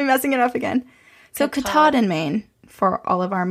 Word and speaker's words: Katahdin: So I had said that the Appalachian Trail runs from Springer Mountain, Georgia to Mount Katahdin, Maine, and I pronounced the name wrong in Maine. My mom was like, probably no one noticed or knows how you actually say it, but I'm going to tell you because she's from New Hampshire. It's Katahdin.